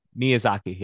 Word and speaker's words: Miyazaki: How do you pronounce his last name again? – Miyazaki? – Miyazaki, yes. Miyazaki.